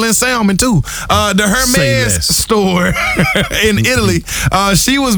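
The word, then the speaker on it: Hermès: Savannah James was on a podcast the other day, and she was speaking about her experience at a Hermes store in Italy. Is that how you see? I always hit the H. – H is silent.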